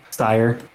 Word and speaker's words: Cyre: So it's definitely not pronounced Cry. We got to figure out how to say this. It's C-Y-R-E. Cyrie? – Not. – Kyrie. – C-Y-R-E. – Siri. – Siri? – Sire. – All right. So we're going to call it Sire, which is known as the crown jewel of Galifar, the country.